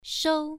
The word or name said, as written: shou1